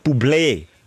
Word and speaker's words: Bublé: Es gibt Bublé, Bubbly. Aber er hat eben ein Egü auf dem E und heisst wirklich Bublé. Bublé. So heisst er. Michael Bublé. Hat es selber gesagt.